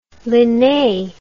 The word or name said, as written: linne